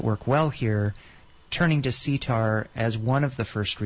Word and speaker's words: CTAHR: but then I will put the word sitar. And that's spelled C-T-A-H-R, which stands for the College of Tropical Agriculture and Human Resources.